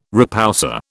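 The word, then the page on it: repoussé: British English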